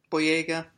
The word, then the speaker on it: Boyega: This time stars John Bodega? – Boyega. – Boyega. I just always say his name is Bodega.